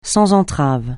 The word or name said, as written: sans entraves